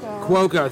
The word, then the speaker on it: Cuoco: I don't know how to pronounce her last name. Thank you. – Cuoco. – Thank you. I was going to say Cuoco, but yeah, close enough.